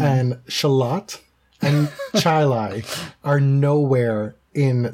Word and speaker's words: Charlotte: Because you have towns named Chile that you pronounce Charlie, and towns that are spelt Charlotte, and you pronounce it Shalot.